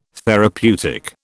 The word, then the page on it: therapeutic: British English